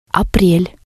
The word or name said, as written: aprel